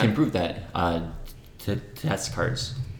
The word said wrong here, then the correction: Descartes: Oh, don't say it like that. It's Descartes.